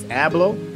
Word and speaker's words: Abloh: So, if you don't know, Virgil, I believe how you pronounce it is Abloh.